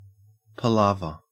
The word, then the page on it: palaver: Australian English